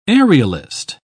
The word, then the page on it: aerialist: British English